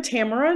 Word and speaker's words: Tamara: Let's go to Dr. Tamara Nall, pronounced like camera. You educated me, and I'm delighted to have you here. Tamara, please introduce yourself. – Hello, everyone. My name is Dr. Tamara Nall.